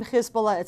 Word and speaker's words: Hezbollah: Remember, the name Hezbollah itself, and again, why I pronounce it Hezbollah, is because Hizb means party, Allah, God, party of God, right?